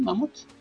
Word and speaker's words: Mammoth: ¿Sí lo pronuncié bien, Mammoth, mi estimado Mario? – Sí, sí, sí.